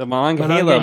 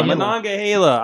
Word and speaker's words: Monongahela: What the Monongahela? Monongahela. I'll say that right.